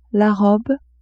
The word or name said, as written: robe